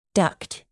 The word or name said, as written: ducked